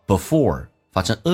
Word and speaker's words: before: before 这个单词，这个 e 可以弱化成 a, 因为它主重元音是 or, 所以非主重元音的 e 可以弱化成 a, 叫 before.发成 a 这个发音 before he was even born.